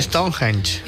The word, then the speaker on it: Stonehenge: Se dice Stonehenge.